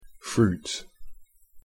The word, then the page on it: fruit: English